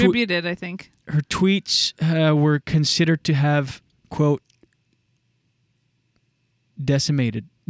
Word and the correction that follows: disseminated: No, disseminated. – Spell it. – D-I-S-S-E-M-I-N-A-T-E-D. Disseminated. Yeah, disseminated information to disturbing. – Okay. – Yeah, not decimated. Disseminated.